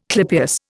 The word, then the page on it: clypeus: Indian